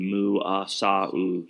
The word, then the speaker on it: Muasau: They've got a new quarterback from, Logan Muasau. That's how you pronounce it. It's even there's a pronunciation guide on their roster.